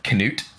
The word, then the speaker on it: Cnut: There's actually a number of different ways I guess you can pronounce this. I kind of prefer, since the spelling is C-N-U-T, to just say the pronunciation of Knut, so that's what I'm going to say.